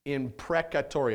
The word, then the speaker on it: imprecatory: Psalm 35 is typically referred to as what is called an imprecatory psalm. You might be like, what the heck does that mean? Imprecatory.